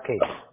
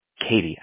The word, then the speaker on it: Katy: And that's K-A-T-Y? – Correct. It's pronounced Katie. – Katie. I don't know why I keep saying Katy.